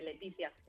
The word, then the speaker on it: Leticia: Se pronuncia exactamente igual que en español, Leticia.